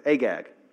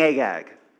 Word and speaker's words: Agag: I brought back King Ahag. Or what's his name? Whatever. Agag. The G's are sometimes pronounced as H's. I brought back King Agag.